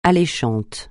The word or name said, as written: alléchant